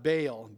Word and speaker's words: Baal: In 1 Kings 18 is the account of Elijah challenging the 450 prophets of the god Baal, B-A-A-L. Or maybe Baal, as I have heard it pronounced.